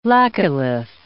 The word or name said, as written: laccolith